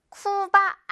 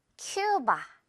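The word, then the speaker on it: Cuba: 자, 첫 번째로 쿠바 아니죠. 영어 발음상 큐바, 큐바입니다.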